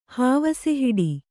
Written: ಹಾವಸೆಹಿಡಿ

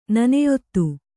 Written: ನನೆಯೊತ್ತು

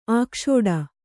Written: ಆಕ್ಷೋಡ

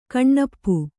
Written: ಕನಪು

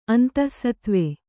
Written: ಅಂತಃಸತ್ವ

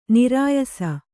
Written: ನೆರೆಯಿಸು

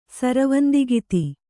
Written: ಸರವಂದಿಗಿತಿ

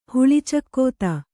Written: ಹುಳಿಚಕ್ಕೋತ

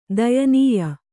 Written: ದಯನೀಯ

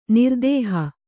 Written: ನಿರ್ದೇಹಿ

